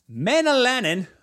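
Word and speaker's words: Mäenalanen: Saku Manninen. Manalainen, I believe that's how you say his name, scored the empty net goal.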